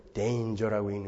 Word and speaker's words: danger: 거기는 그 G는 그 발음이 아니라 이제 G 발음이 나는 거다. 그러니까 그런 거 할 때는 danger라고 읽는 거고 stranger라고 하는 거다.